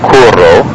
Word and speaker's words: curo: Again, curo, curro. Notice I accented the U a little bit there, but the important thing to notice is that the double R is a bit longer.